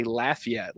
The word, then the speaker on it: Lafayette: The Cajuns down there, they say Lafayette, like Laffy Taffy. They say Lafayette.